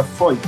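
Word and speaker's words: Feucht: Se escribe Feuch, pero se pronuncia Foy. Sean Foy.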